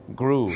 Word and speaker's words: grew: มันจะต้องออกเสียงสระอูครับสระอูถูกต้องครับอย่างเช่น grow ก็ต้องกลายเป็น grew